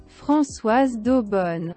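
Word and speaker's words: Françoise d'Eaubonne: de, Françoise Daubonne.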